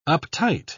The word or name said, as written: uptight